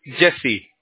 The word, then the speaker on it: Jesse: Jesse is my name.